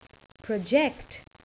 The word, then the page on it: project: verb